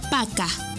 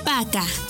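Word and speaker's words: пока: P-A-Y-A-L-U-S-T-A. Payalusta. ¡Muy bien! Para finalizar, la palabra adiós se dice Paca. Paca.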